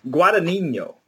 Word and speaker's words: Guadagnino: Guadagnino.